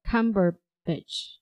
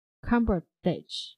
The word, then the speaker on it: Cumberbatch: It's like Benedict Cucumber. – Cucumber. – 就是卷福的信我老是念成 Cucumber, 其实是 Cumberbatch, 对吧 ?Cumberbatch.